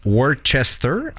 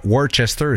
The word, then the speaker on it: Worcester: Tu t'en vas dans la ville de Worcester, entre guillemets, ouais. – Dans le Massachusetts. C'est pas Worcester, c'est Worcester. Ils prononcent ça de même.